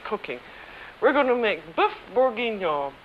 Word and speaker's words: boeuf bourguignon: How do you say it, Alex? – It's boeuf bourguignon.